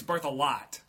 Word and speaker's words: Berthelot: It's Canadian. I know that. So, but I know that it's birth a lot. – Yeah, it's spelled like birth e lot, like B-E-R-T-H, E-L-O-T.